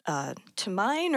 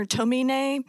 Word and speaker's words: Tomine: It's called Shortcomings by Adrian Tomine or Tomine. He kind of goes back and forth on how his last name is pronounced.